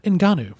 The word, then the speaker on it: Ngannou: Did I say Ngannou right? – No. – Good. How do you say it? – Francis Ngannou. – Ngannou. You know why I said Ngannou? Because the N is silent.